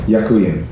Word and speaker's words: Ďakujem: Ďakujem.